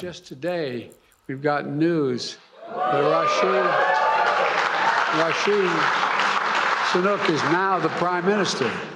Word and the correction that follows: Rishi Sunak: Rashid Sunak. I mean, that was pretty breathtaking to get both parts of his name wrong.